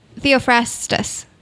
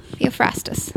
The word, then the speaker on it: Theophrastus: Oh, okay. Theophrastus. Yeah. Theophrastus. That's how I would pronounce it.